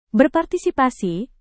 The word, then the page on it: berpartisipasi: indonesian